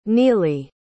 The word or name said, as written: nillee